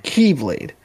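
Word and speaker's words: Keyblade (χ-blade): The Keyblade is not spelled Keyblade, K-E-Y. It's spelled X-Blade, but it's pronounced Keyblade.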